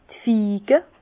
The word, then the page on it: d' Fige: Bas Rhin; Herrlisheim